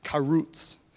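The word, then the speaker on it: charutz: So I just was just reading about this, and that word decision there is the Hebrew word, and it's pronounced karutz. I listened to the little dude, you can actually press it and it pronounces it for you. And he's like, karutz. I was like, there you go, karutz.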